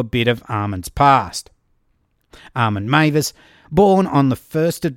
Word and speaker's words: Meiwes: FYI, his name is spelled M-E-I-W-E-S, just in case you want to search for him, but of course it's pronounced Meiwes.